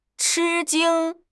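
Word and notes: chi1 jing1: tones 1, 1

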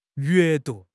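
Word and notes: yue4 du2: tones 4, 2

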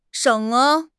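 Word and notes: sheng3: tone 3

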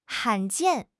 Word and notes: 罕见: HSK6

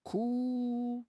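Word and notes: ku3: tone 3

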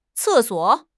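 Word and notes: ce4 suo3: tones 4, 3

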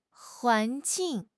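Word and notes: huan2 jing4: tone 2; tone 4

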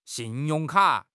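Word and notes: xin4 yong4 ka3: tone 4; tone 3

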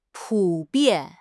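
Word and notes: pu3 bian4: tones 3, 4

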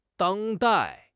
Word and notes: 当代: HSK6

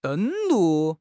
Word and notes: 登陆: HSK6

